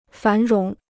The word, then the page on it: 繁荣: fán róng